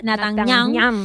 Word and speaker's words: Netanyahu: Benjamin Netanyahu, ¿cómo se dice? Netanyahu.